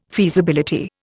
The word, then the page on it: feasibility: Indian